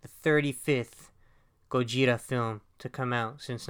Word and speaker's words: Gojira: We're going to be entering the Godzilla dimension, or it's actually pronounced in Japan, the Gojira dimension.